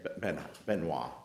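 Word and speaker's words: Biên Hòa: Is that the way to pronounce it? – Yeah, Benoit, I believe, is the correct pronunciation.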